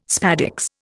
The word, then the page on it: spadix: Indian